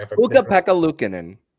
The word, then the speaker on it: Ukko-Pekka Luukkonen: But just because, you know, they're trying to deflect, despite the fact that Ukko-Pekkanen. I'm so sorry. – Ukko-Pekka Luukkonen.